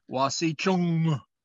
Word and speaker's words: wasicu: It's it's commonly spelled W-A-S-I-C-U. – Okay. – But in the Lakota language, these words often end in the word, in the letter N, and that N is pronounced with a guttural sound, or a glottal sound. It's wasichung.